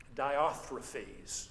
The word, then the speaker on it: Diotrephes: But as we serve God, as we seek to do that, beware of being diotrephes. So, is that how you pronounce it? Yeah, right.